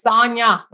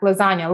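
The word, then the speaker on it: Sonja: Her name is spelled with a J, but it's Sonia. She say like lasagna, lasagna. I mean, not sorry, not lasagna. Sonia, not lasagna.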